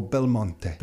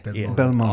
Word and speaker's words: Belmonte: Vince, how do I say your last name properly? – Belmonte. – Oh, Belmonte. – Belmonte. Oh, I like the accent at the end. – Belmonte. – Beautiful.